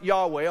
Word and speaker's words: Yahweh: That word that's translated Lord is the Hebrew word that's written capital Y, capital H, capital W, capital H. It's sometimes pronounced Yahweh, although there are some people who say you shouldn't try and pronounce it, and whatever way you pronounce it, you're not doing it right.